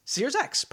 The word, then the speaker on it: Searx: It's S-E-A-R-X. – SearsX. – SearsX. Perfect.